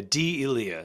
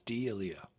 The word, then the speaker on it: D'Elia: Yeah, D'Elia is actually how you say it. It's capital D, capital apostrophe, capital E. So it's D'Elia.